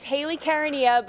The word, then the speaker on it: Hayley Caronia: It's Hayley Caronia, but people struggle.